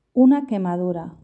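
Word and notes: una quemadura: Spanish (Spain)

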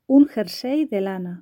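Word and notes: un jersey de lana: Spanish (Spain)

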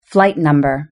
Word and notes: flight number: British English

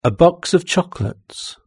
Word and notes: a box of chocolates: British English

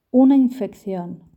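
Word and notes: una infeccion: Spanish (Spain)

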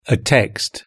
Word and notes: a text: British English

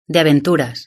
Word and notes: de aventuras: Spain

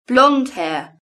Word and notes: blonde hair: British English

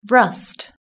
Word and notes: rust: British English

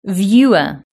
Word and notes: viewer: British English

